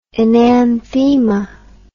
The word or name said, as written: enanthema